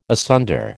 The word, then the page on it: asunder: American English